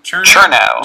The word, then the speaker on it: Chernow: Chernow.